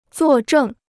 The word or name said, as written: 做证